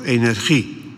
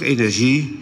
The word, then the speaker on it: energie: Of moet je dat uitspreken met de G van genoeg, energie? Is het nou energie of is het nou energie? Interessant om over na te denken. Van Dalen meldt beide varianten. Maar de ZJ-uitspraak, energie, is in het Nederlands het meest gewoon.